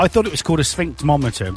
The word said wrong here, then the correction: sphygmomanometer: but... – I don't even think I can pronounce it. – Go on, pronounce it slowly. – Right. It's a sphygmomanometer.